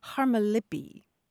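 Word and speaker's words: harmalippi (χαρμολύπη): but there is a term in modern Greek, harmalippi, which is spelled C-H-A-R-M-O-L-Y-P-E, harmalippi, or harmalippi.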